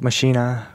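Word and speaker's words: Maschine: It's like spelled similar to machine. – Oh, machine, yeah. – Well, it is machine. It's the native instruments. – I heard some people say it like machina or something. – Machina. German maybe.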